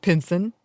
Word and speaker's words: Pinson: There's a butter bean festival in Pinson, Alabama. I apologize if I mispronounce that, but looks like Pinson. – There you go.